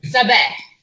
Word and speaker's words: Sabah: Do you want the Lebanese pronunciation? – Yeah, go for it. – Alright, Sabah.